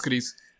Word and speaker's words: Gris: Everyone pronounced it as Gris. – Gris, yeah. – And I hate it. – Yeah, I mean, it's like... It's Gris. Like, it's just because you're talking in English, it just sounds like Gris, a musical.